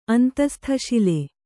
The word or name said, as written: ಅಂತಸ್ಥಶಿಲೆ